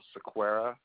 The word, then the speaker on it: Sequeira: Last name, Sequera. – Let's spell that last name. – S-E-Q-U-E-I-R-A.